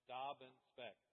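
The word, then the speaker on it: Dobbinspeck: As Pastor Brad had the same problem, my last name is pronounced Dobbin Speck, like a dob of something and a speck of something, in case you're trying to.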